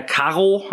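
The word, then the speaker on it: Carro: Der Herr Caro wird er so ausgesprochen.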